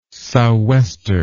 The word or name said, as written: sou'wester